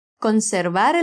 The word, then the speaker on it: conservar: Esse som do V em conservar é como o B mais sutil do espanhol. Isso mesmo, além do B como do português, o espanhol tem esse outro som de B, um que não chega a encostar os lábios. Tenta falar essa sílaba comigo das duas formas para notar a diferença. Com o som mais forte, bar. E agora, sem encostar os lábios. Var.